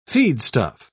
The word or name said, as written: feedstuff